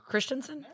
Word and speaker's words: Christensen: Christensen? – I said it right. – You got it.